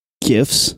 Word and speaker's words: GIF: Graphic starts with a G with a hard G. So I think you're just being difficult on purpose, creator of GIFs.